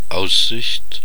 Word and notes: view: /vjuː/

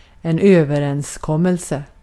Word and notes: agreement: American English

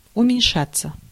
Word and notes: descendre: /de.sɑ̃dʁ/

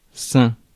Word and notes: sain: France; [sɛ̃]

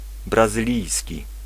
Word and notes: brésilien: France; [bʁe.zi.ljɛ̃]

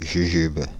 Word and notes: jujube: /ʒy.ʒyb/

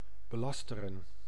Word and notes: calomnier: France; /ka.lɔm.nje/

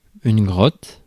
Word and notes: cave: [ˈkeɪv]

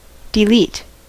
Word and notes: delete: American English

